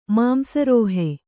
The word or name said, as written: ಮಾಂಸರೋಹಿ